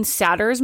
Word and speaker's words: Satter: Satter's model. So it's S-A-D-E-R, I believe. And if you look up intuitive eating for kids... – Is it Sater? – She pronounces it Satter.